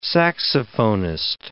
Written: saxophonist

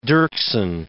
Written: Dirksen

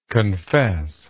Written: confessingly

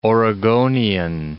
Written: Oregonian

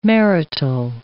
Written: marital

